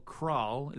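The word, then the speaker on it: Cralle: Krall. Is that the right way of pronouncing yes.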